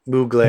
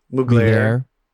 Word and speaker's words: Mugler: Mugler? – Mugler. – How do you say that? – Mugler.